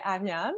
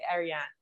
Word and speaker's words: Ariane: If I say it in French, I say Ariane. Mm-hmm. But if I say it in English, I'll say Ariane.